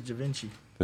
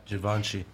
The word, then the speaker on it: Givenchy: This is Da Vinci. – This is crazy. – Did I say it right? – Givenchy.